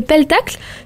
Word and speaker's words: Pelletacle: Seb l'a rappelé, vous aimez la télé vous aime, mais aujourd'hui donc nous parlons de votre nouveau spectacle, le Pelletacle, si oui. – C'est bien comme ça que ça se prononce.